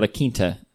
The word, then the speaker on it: La Quinta: Yeah, La Quinta.